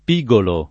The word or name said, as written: pigolare